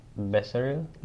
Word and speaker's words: Becerril: Eric Becerril. – Becerril. – Did I pronounce that right? – Pronunciation? You have that Spanish pronunciation.